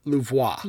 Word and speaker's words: Louvois: It is Captain Philippa Louvois. – Louvois. Okay, see. – Louvois. – It was French, Louvois.